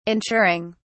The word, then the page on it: ensuring: British English